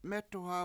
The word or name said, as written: mEtu7ha